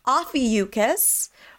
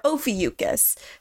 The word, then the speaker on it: Ophiuchus: but they provide two pronunciations for the constellation, which we've been using so far. So Ophiuchus or Ophiuchus.